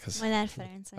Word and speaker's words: Molnár Ferenc: Molnár Ferenc.